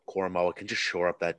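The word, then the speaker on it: Koramoah: And I'm going to butcher this name from Notre Dame, Jeremiah Owusu-Koramoke. – Koramoa.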